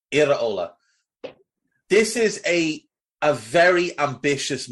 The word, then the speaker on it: Iraola: And by 3 p.m., they had announced their new manager, whose name, I'm going to go with Andoni Iraola. I'm going to allow you to correct me on that. – It's pretty, you know, close, to be fair.